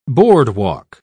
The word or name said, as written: boardwalk